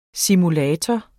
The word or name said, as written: simulator